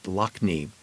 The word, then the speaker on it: Lough Neagh: The largest freshwater lake by surface area in the UK is Lough Neagh, pronounced Lochney.